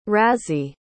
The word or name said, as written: razee